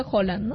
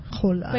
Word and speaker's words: Haaland: Bueno, aunque dicen que se pronuncia Haaland, ¿no? – Haaland.